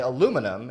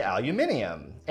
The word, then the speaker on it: aluminium: So we say aluminum in America. Mm-hmm. British speakers will say aluminium. And it's not just pronounced differently. They spell it differently. They spell it with an I.